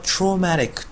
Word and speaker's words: traumatic: この T は日本で言うトラウマの形容詞ですが、発音は traumatic というふうに O を伸ばす音ですね。